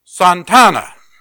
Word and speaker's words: Santa Ana: And I remember one time I was down there and I stayed in Santana. Now, you're looking over my shoulder and you're probably saying, well, that says Santa Ana. Well, that's what we would say, but that's the way they pronounce it, Santana.